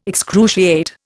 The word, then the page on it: excruciate: Indian